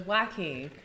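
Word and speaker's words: Joakim: It's spelled with a J though. It looked like Joaquin, but it's Joaquin.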